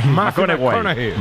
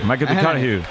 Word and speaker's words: McConaughey: Hay que ir corrigiendo la pronunciación. – McConaughey. McConaughey. Bueno, pues el Matías.